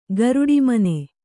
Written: ಗರುಡಿಮನೆ

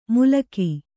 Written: ಮುಲಕಿ

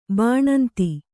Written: ಬಾಣಂತಿ

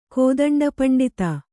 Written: ಕೋದಂಡಪಂಡಿತ

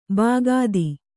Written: ಭಕ್ತ

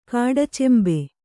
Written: ಕಾಡಚೆಂಬೆ

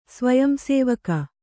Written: ಸ್ವಯಂಸೇವಿಕೆ

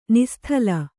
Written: ನಿಸ್ತಲ

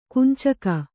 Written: ಕಂಚುಕ

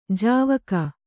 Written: ಜಾವಕ್

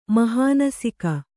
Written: ಮಹಾನಸಿಕ

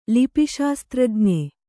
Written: ಲಿಪಿಶಾಸ್ತ್ರಜ್ಞ

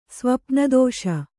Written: ಸ್ವಪ್ನದೋಷ

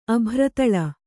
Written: ಅಬ್ಬರತಾಳ

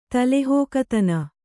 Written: ತಲೆಹಾಕುತನ

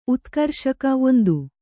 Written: ಉತ್ಕರ್ಷಕ

